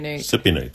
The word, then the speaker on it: cipionate: What I do is I have testosterone cipionate. And that's how you pronounce it, right? – Cipionate, thank you.